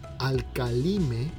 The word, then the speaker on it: Alkalime: Ah, en específico, este producto que tiene John Living se llama Alkaline o Vamos a, pronunciarlo como se escribe, como debe ser en español. Alcalime, y es con K, Alcalime.